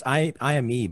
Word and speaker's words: Ime: But it's Ime. It's IME, but I've never heard it. Yeah. So I don't know how to pronounce it. It's IME. So it's Ime.